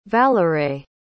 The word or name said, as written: valeray